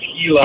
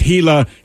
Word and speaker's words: Gila: I bred, sorry, you guys were talking about Gila monsters earlier, and that's, I'm sorry, it's how it's pronounced, is Gila. – Oh, trust me, everyone on the text line let us know it's Gila, it's spelled with a G.